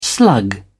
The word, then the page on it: slug: British English